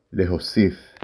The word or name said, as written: להוסיף